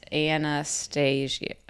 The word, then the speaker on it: Annahstasia: Yeah, it's A-N-N-A-H-S-T-A-S-I-A. Anastasia. That's how that's how you say it.